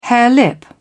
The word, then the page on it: harelip: British English